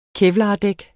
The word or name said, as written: kevlardæk